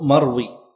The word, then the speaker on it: مروي: وقوله مروي بسكون الياء للوزن، فالياء هنا تكتب خطا ولا تنطق لفظا، تكتب خطا ولا تنطق لفظا، مراعاة للنظم، فلا يقال عزيز مروي اثنين أو ثلاثة، لأنه إذا قيل ذلك فإن البيت ينكسر.